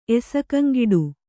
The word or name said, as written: ಎಸಕಂಗಿಡು